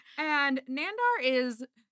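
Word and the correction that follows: Nahdar: Not Nandar. God damn it. I can't read my own handwriting and I keep mispronouncing his name. Nadar.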